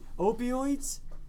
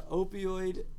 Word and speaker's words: opioid: How do you say it? – Opioid. – What? – Opioid. – Am I saying it right? – Yeah. – It sounds weird. Mm-hmm. It sounds like onomatopoeia.